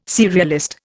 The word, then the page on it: serialist: Indian